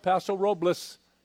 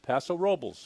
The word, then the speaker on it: Paso Robles: Some people call it Paso Robles, but it's pronounced Paso Robles, folks.